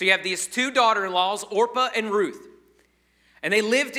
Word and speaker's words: Orpah: Fun fact there, Oprah Winfrey is actually, her real name is Orpah, but people mispronounced it so much, she just like said, fine, I'm Oprah from now on.